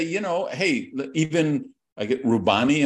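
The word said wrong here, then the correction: Roubini: Am I saying his name right? – Rubini, yeah.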